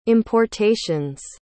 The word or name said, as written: importations